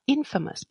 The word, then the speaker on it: infamous: famous, que es famoso, si le ponemos in delante, se pronuncia distinto, sería infamous, pero sería como decir famoso o infame.